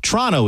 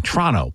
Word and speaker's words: Toronto: Toronto is how you say it if you're Canadian. Toronto.